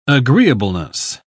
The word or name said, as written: agreeableness